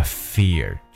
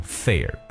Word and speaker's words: fear: 当然了，那在保持信仰的时候呢，需要 drop the fear，就是我们所说的放下自己的恐惧。各位对这个单词的发音要特别注意一下，就是 fear，f e a r，fear，千万不要把它读成 fear，不然就变成了另外一个词汇。